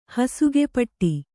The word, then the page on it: ಹಸಿಗೆಪಟ್ಟಿ: kannada; hasige paṭṭi